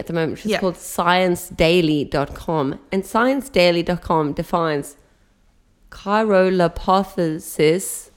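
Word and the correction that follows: cryolipolysis: That's not how you say it, though. – Okay, can you say it? – No. – Chirolipolysis.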